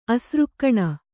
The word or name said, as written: ಅಸೃಕ್ಕಣ